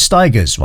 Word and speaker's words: Stigers: who always used to say Curtis Stigers rhymes with tigers.